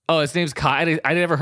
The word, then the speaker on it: Cayeux: It's spelled C-A-Y-E-U-X. How is that pronounced? Cayeux? Yeah, it's French. Yeah, that's the kid's show. – Cayeux? – Yeah. Cayeux.